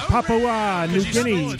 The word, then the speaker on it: Papua New Guinea: That's pronounced Papua New Guinea. It's all one connective thing.